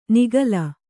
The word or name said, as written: ನಿಃಕಲ